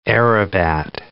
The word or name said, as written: aerobat